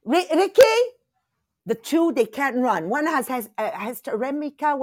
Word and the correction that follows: hysterectomy: How do you say that in English properly? Hysteremica, ovaries removed. – Okay. Hysterectomy. – That's it, and she can't run.